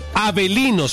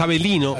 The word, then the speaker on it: Avellino: Avelino se llama, se escribe Avellino, pero es Avelino de la Serie B de Italia.